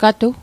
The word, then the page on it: gatu: Mi'gmaq